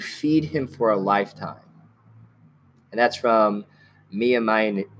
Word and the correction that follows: Maimonides: I think that's how you say it. – Well, Mia would be M-I-A. – Oh, Maimonides. There we go. – There we go. – Maimonides. Thank you.